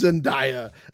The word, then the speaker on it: Zendaya: That's Zendaya.